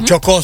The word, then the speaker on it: Chocó: No se pronuncia, Chocó, ¿es que se dice? – Uh-huh. Chocó, sí, con Chocó, acento en la O.